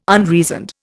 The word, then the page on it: unreasoned: Indian